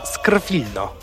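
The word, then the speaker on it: Skrwilno: Niełatwo też wymówić nazwę tej drużyny. Skrfa Skrfilno.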